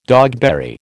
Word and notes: dogberry: American English

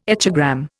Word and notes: echogram: Indian

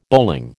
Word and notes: bowling: American English

